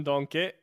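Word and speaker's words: Denkey: and also, still on the topic of Kevin Donke, that's how you should pronounce it. That's how he pronounced it himself a few weeks ago, I believe.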